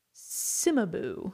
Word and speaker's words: Cimabue: Cimabue. Okay. C-I-M-A-B-U-E.